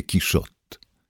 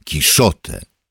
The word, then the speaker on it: Quijote: Francuskie Kiszot, niemieckie Kiszote oraz włoskie Kiszotte i Kiszoć, wymawiane po portugalsku, także Kiszoci, to inne sposoby wymowy hiszpańskiego Quijote. Sam Cervantes w hiszpańszczyźnie swoich czasów prawdopodobnie powiedziałby Quijote. W niniejszej powieści proponuję stosować elegancką francuską wymowę Quijote z powodów, które staną się jasne za sprawą samego tekstu.